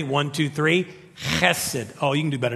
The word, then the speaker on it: hesed: That is a Hebrew word that is spelled H-E-S-E-D. We might say in West Texas, hesed. But to say it in Hebrew, it's actually hesed. And you have to say it like you're trying to hawk something up.